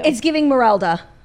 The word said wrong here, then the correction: Merida: What do we say her name is? – Merida. – Merida.